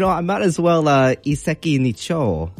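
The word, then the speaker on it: iseki nicho (一石二鳥): So again, it's going to be iseki nicho. So it's going to be pronounced in two different words here. So the first one is iseki, iseki, and the second part is nicho, nicho.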